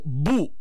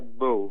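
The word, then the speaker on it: Beau: se dico Bu a paura e non Bo. – No, allora credo che la pronuncia corretta sia Bo.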